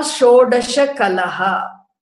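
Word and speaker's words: Shodasha kalaha (षोडश कलाः): You have to learn to say it correctly. First cerebral shakara, then we have the palatal shakara. Shodasha kalaha.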